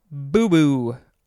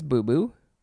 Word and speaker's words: boubou: Next is boo-boo. I think it's boo-boo. B-O-U-B-O-U. Noun from 1961.